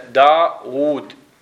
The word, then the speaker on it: داود: داود فودع على النحات قال لهم احنا داود نلفظ واوين بينما من نكتب ايش نكتب واو واحدة